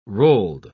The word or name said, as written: rolled